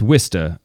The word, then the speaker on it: Worcester: Yeah, it's Worcester. That's not how it's spelled. – Mm-mm. – This is a lot like Scotch, where the spelling of the thing and the pronunciation of the thing are disassociated, and that's okay.